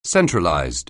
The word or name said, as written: centralized